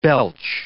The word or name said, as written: belch